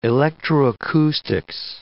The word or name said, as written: electroacoustics